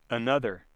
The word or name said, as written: another